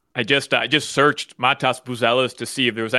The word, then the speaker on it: Matas Buzelis: Montez Buzelis. That's how you say his name? – Matas Buzelis.